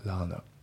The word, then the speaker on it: learner: あと発音ですけど、 learn a のところがリンキングで learner、 learn a じゃなくて learner。